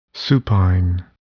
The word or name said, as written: supine